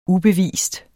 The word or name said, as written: ubevist